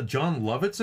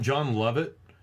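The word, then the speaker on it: Jon Lovitz: But it's one of those times where JR tries to make a reference to pop culture and mispronounces the name, and he calls him John Lovett.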